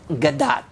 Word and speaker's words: Gadot: It is actually pronounced Gadot.